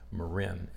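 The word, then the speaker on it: Morin: I think she pronounces her name Marin. It's M O R I N.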